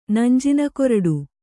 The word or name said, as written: ನಂಜಿನಕೊರಡು